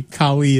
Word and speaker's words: Kali: Yeah, if you're interested in security, Kali is the one you want. K-A-L-I.